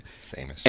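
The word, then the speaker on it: Séamus: It's spelled no Seamus, H. But it's pronounced Seamus.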